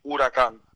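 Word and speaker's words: Huracán: It's a hurricane in Spanish. Huracan. – Huracan.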